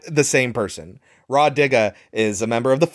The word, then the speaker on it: Rah Digga: And Ra Diggs, you're not a good actress. I'm sorry, I'm totally getting her name wrong. It's Rah Digga.